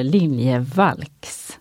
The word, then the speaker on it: Liljevalchs: nu har de tagit reda på hur man ska uttala det och då, man ska uttala det liljevalchs.